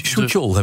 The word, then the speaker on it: Suk-yeol: Yoon Suk-yeol, ja. Een conservatief, uitgesproken voorstander van Suchol, heb ik laten vertellen. – Suchol, nou, dankjewel.